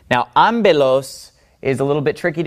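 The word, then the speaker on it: ambelos (ἄμπελος): Usually, this letter makes the P sound, but whenever P comes after me, it actually makes a B sound. That's just an exception to the rule. So every time you see a P following a me, you always pronounce it like a B. Therefore, this word is pronounced ambelos. Ambelos.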